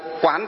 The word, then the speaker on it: Quán: Cho nên cái từ quan này hay từ quán này có thể đọc thành hai đều được. Ví dụ Nam Mô Quán Thế Âm Bồ Tát hay Nam Mô Quan Thế Âm Bồ Tát đều được cả. Nhưng mà nếu đọc đúng thì phải đọc là quán thì đúng hơn.